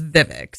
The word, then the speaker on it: Vivek: I said Vivek, and you laughed. Is it Vivek? What's the pronunciation of the name? – I, have heard him introduce himself as Vivek.